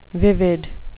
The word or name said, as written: vivid